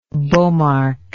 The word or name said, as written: Bomarc